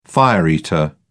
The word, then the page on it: fire-eater: British English